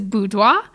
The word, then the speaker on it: boudoir: Here in Kentucky, it is very common for people just to say boudoir. Boudoir, I just like a bou and a doir. But technically, it is a French word. It's the French word for bedroom. And so the technical pronunciation is boudoir. But you will get some sideways glances if you say boudoir in Kentucky. And so boudoir is just fine.